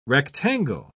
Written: rectangle

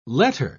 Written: letter

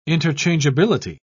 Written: interchangeability

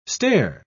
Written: stair

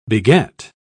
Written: beget

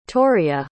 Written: toriah

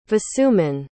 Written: vasuman

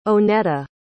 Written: oneta

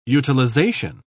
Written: utilization

